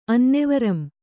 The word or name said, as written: ಅನ್ನೆವರಂ